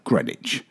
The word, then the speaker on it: Greenwich: Greenwich Village in New York City and Greenwich, Connecticut are pronounced in the same manner as Dunwich in the UK, Greenwich.